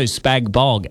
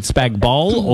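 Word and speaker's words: spag bol: Ian Bell Post Hill, is it spag bowl or spag bog? – Spag bowl, the G is silent.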